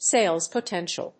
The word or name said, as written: sales potential